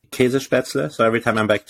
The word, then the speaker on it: Käsespätzle: Käsespätzle.